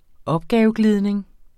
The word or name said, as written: opgaveglidning